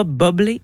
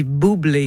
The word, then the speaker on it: Bublé: Parce que Michael Bublé, on dit pas bobblé, on dit boublé. Mm-hmm. On dit pas bubble non plus.